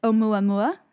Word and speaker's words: Oumuamua: I looked up the pronunciation. It's Oumuamua. Pretty sure is how you say it.